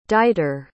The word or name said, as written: deiter